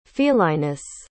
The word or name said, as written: felinus